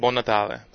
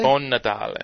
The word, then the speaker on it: Buon Natale: Buon Natale. – Buon Natale? – Buon Natale.